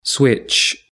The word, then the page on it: switch: British English